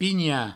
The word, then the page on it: pinhar: pigna